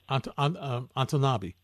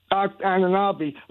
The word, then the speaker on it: Anunoby: You say his name for me, the wing on Toronto, A-N-O-N-B-O-Y. – Anunoby. – Anunoby.